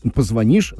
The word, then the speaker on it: позвонишь: Ну а по теме грамотности ухо режет, когда в слове «позвонишь» ставится ударение не на последний слог, да.